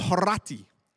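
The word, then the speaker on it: Horatii: It hangs in the Louvre, and it's called The Oath of the Horati. And I roll my R's because in the Latin, you're supposed to roll your R's.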